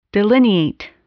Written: delineate

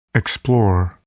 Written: explore

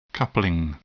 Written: coupling

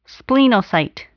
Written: splenocyte

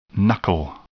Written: knuckle

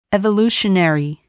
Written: evolutionary

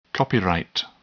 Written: copyright

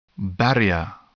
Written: barrier

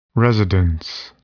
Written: residence

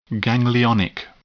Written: ganglionic

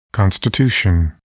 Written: constitution

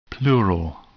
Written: pleural

